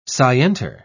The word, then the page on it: scienter: British English